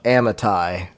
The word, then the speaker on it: Amittai: So, the word of the Lord came to Jonah, son of Amittai, right? Is that how you say it? – Sounds good to me.